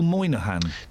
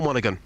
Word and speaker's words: Monaghan: When you say Paul Monaghan, do you mean do you mean Paul Moynihan? – No, Paul Monaghan. – How is that spelt? – That is spelt M-O-N-A-G-H-A-N.